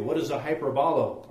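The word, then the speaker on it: hyperbolo (ὑπερβολή): Now, the more we understand, now the word exceedingly here is found toward the bottom, that last definition, and the Greek pronounces it different than we call it a hyperbole. They say hyperbolo, now, or hyperbole. Now, what's the word parable?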